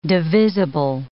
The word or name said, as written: divisible